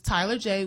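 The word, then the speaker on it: Tyeler Jae: But on my Instagram, it is Tyler J, which is TYELER_JAE.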